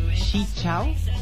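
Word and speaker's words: Xin chào: So Specs was saying, Chi Chao. That's Vietnamese for hello. Chi Chao. – Oh. – X-I. How do you pronounce X-I? Chi.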